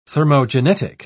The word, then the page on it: thermogenetic: British English